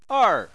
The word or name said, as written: er4